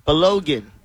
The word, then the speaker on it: Balogun: Balogun is his last name.